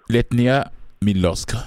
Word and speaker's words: Letnia miłość: Letnia Milosk. Est-ce que j'ai bien prononcé? – Oui, c'est bien prononcé.